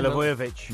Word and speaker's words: Milivojevic: Luka Milovojevic. How do you say it, Danny? – Milivojevic. – Milivojevic. – I do struggle with those Serbian names.